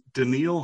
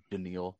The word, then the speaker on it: Daniil: A guy named Daniil, I'm not going to give him as an answer. It's spelled like Danielle.